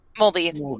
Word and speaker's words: Mulvey: Mulvaney, Molly, am I saying that right? I'm terrible with names. – Mulvey. – Okay.